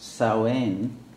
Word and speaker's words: Samhain: as you may know, its original name was not Halloween, but what is actually in Gaelic pronounced Samhain, something close to that. And then you wonder why it's spelled as if it's Samhain.